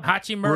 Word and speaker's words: Hachimura: Hachimura.